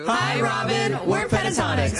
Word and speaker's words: Pentatonix: Pentatonix. – Correct. – Pentatonix.